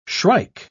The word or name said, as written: shrike